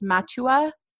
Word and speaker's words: Matua: I think it's pronounced Matua. I'm pretty sure.